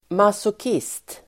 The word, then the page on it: masochist: [masåk'is:t]